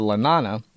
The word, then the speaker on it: Lanana: Back here in East Texas, one of two creeks running through Nacogdoches is called the Lanana, but it rhymes actually with banana.